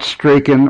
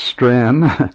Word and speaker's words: Strachan: It's not Strachan or Strachan, it's Strand.